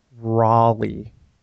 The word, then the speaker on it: Raleigh: Is that pronounced Raleigh? – Daniel Lynn Raleigh. – Like Sir Walter Raleigh, who invented the cigarette? – Or Raleigh, North Carolina.